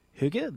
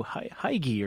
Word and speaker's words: hygge: It's called hygge. This is spelled H-Y-G-G-E. You might have seen it and initially thought, oh, hygge or something like that. Hygge is the pronunciation.